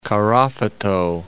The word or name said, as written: Karafuto